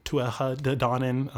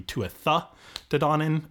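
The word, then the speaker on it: Tuatha Dé Danann: Um, the, the ones that might be more familiar to people are known as the Tuatha uh, or Tuatha Danann uh, in sort of the modern Irish pronunciations. It would be Tuatha Danann in the um, in the Old Irish,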